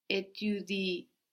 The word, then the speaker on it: étudies: So here, étudie is spelled E-T-U-D-I-E-S. But again, it just sounds like étudier without the E ending.